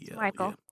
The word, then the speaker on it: Michael: It's spelled Michael, but I want to say Michelle. But is it M-I-C-H-E-L? – A-E-L. – Michael.